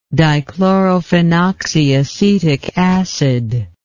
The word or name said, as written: dichlorophenoxyacetic acid